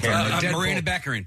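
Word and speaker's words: Morena Baccarin: Maria Bacca from I can never pronounce her name. – Marina Beckerin.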